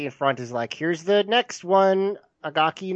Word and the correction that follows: Akagi: Noburo," or Akagi, sorry.